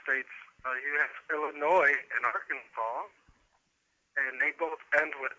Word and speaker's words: Arkansas: Well, Arkansas is the way it's spelled and the way we would ordinarily pronounce it, but it's Arkansas.